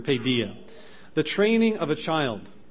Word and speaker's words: paideia: That word is translated from the Greek word paideia. That's spelled P-A-I-D-E-I-A. P-A-I-D-E-I-A. Paideia.